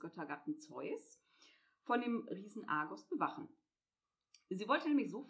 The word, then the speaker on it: Argos: Argus oder Argus-Augen ist benannt nach dem 100-äugigen Riesen Argos oder auch eben Argus ausgesprochen, der Allesseher.